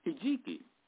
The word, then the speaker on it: hijiki: Let me spell that for you. It's H-I-J-I-K-I, hijiki.